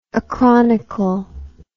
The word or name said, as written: acronical